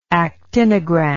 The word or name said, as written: actinogram